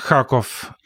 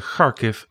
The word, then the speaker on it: Kharkiv: En dan was het altijd Kharkov en eh, Kiev. En je gaat het net iets anders uitspreken. Kharkiv, Kiev.